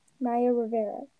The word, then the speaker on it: Naya Rivera: Is that how you say it? Naya Rivera? – Naya Rivera. – Naya Rivera.